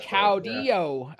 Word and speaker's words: Caudillo: He goes by Nuclear Caudillo. Is that how you pronounce it? – Perfect. Okay. More emphasis on the E-yo on the last Caudillo. – Part. Yeah. Perfect. There you go.